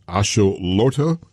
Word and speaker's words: axolotl: It's for the World Cup, it is a salamander, and it's pronounced axolotl.